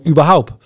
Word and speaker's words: Überhaupt: Wil ik gebruik maken van mijn telefoon überhaupt? Met een T op het eind, hè? Überhaupt.